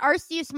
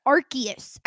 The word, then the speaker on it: Arceus: I've pronounced it Arceus my whole life because I'm stupid. They said Arceus every time, like Latin style, hard C's only.